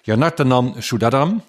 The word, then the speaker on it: Yanartanan Sudadam: Ik ga het goed uitspreken, denk ik. Yanartanan Sudadam. – Dat zeg je helemaal goed.